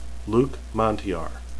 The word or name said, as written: luc